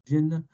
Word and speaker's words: Genna: am I saying it right? G-E-N-N-A, Genna? Genna? I got to say what the Genna, Genna, Genna. Genna, yeah.